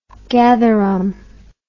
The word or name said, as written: gatherum